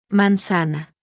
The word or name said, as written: m